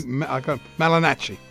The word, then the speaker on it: Malignaggi: Malignaggi. – Yeah, correct. – Is that how you pronounce it? – Yes.